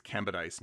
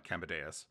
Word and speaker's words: Kambadais: George Kambadais, whose name I mispronounced last week. It's Kambadais, not Kambadais. I thought it was like a dais, like an altar, but no, it's George Kambadais.